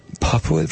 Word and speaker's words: pulpwood: Only pronounce it pup. P-U-P. What do? I drive a pulpwood truck. Pupwood?